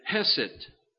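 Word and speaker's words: hesed (חסד): And the word mercy from the Hebrew is the word hesed, is the how you pronounce that.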